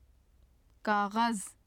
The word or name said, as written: kaghaz